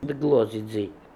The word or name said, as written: Dglozidzi